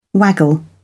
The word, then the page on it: waggle: British English